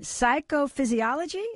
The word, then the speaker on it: psychophysiology: And Brenda is the director of mind-body medicine for RC Cancer Centers, where the latest research, and correct me, Brenda, if I say this wrong, in psychophysiology, is that right? – You've got it.